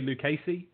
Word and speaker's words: Lucchesi: So finally, we have Joey Lucchesi. I know that's pronounced right because he was the starter I went to watch at the Padres against Gio Gonzalez on Wednesday at 9:10 Eastern.